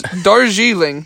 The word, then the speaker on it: Darjeeling: It's Darjeeling. Darjeeling. – D-A-R-J-E-E-L-I-N-G.